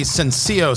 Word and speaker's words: Sencillo: So if you get the chance to check out the Sencillo cigars, S E N C I L L O, Sencillo, that's how it's uh, pronounced.